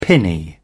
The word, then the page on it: pinny: British English